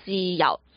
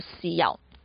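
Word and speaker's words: 豉油: 花生酱，还有辣酱、辣酱、甜酱、甜酱，当然少不了的还有那个酱油啊，西药。酱油在粤语呢，那个发音就很不一样了，变成了酱就变成了西药，西药，西药就是酱油的意思，西药。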